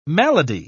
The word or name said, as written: malady